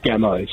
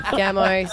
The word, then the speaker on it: Gameau: It's Gameau. So like ammo, but G-A-M-O. – Well, you spell it the wrong way then. – No, that's good. Gameau, sorry.